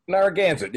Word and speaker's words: Narragansett: Narragansett, yeah.